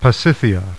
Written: Pasithea

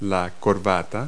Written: necktie

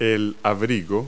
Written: overcoat

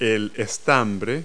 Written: stamen

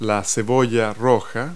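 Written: red onion

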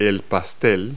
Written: pie

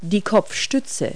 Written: headrest

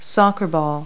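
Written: soccer ball

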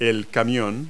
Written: truck

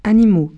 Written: animaux

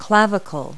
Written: clavicle

